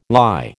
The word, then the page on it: lye: American English